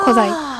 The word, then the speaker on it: 古材: と、古い木材の材と書いて古材と読みます。